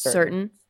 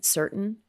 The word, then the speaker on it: Surtain: How do you say his last name? – I think it's Certain. – Mm-hmm. All right, Certain.